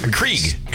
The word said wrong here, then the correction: Keurig: Keurig. – Yeah. – Keurig. – Keurig. Yeah. You don't even know how to say it.